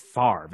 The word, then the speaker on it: Favre: So, you know, before I was like, how can we be pronouncing it as Favre?